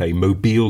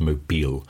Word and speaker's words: Mobil: Yeah, but and this I think it's pronounced, it's spelt Mobil like the oil company, but I think it's pronounced Mobile.